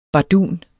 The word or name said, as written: BARDUNERNES